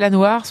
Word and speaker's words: Anwar: Il s'appelle à Noir, son album s'appelle Beautiful Sunrise, et on le découvre dans La Nouvelle Scène sur France Bleu. – La Nouvelle Scène France Bleu, Élodie Suigo. – Et à Noir, à mes côtés, ça s'écrit A-N-W-A-R.